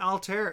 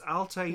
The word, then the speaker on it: Altair: It's not Altair. It's Altair. – Yeah. – It's an Arabic name. It was the Arabs that named all the stars. And so it's, you know, Altair.